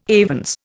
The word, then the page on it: avens: Indian